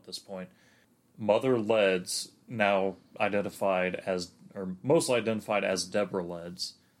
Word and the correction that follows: Leeds: Wouldn't it be Leeds? – Leeds, yeah. I've always pronounced it Leds.